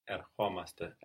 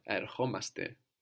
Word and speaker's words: Ερχόμαστε: And with the accent on the o, omaste. So we're coming, we come. – Ερχόμαστε. – Erchomaste.